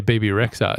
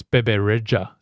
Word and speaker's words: Bebe Rexha: about the way to pronounce her name properly because we say BB Rexha. It's Bebe Rexha.